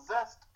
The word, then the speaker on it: ZST: Zest promotion out of Z S. I mean, that's pronounced zest, but it's spelled G S T. I mean, it's spelled Z S T.